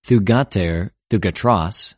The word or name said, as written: θυγατηρ, θυγατρος